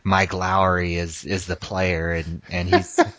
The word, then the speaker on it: Lowry: And the correct pronunciation is Lowry. That's how you pronounce Mike Lowry.